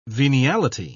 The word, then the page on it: veniality: British English